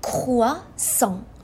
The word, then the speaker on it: croissant: با یه تیر دو تا نشون زدیم پس میگیم croissant croissant croissant درسته؟